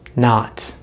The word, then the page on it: not: American English